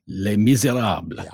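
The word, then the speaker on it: Les Misérables: You had West Side Story, Hamilton, Chicago, Guys and Dolls, and that one that you're going to pronounce because you say it so much better than I do. – Les Miserables.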